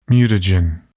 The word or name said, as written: mutagen